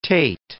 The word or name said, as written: teth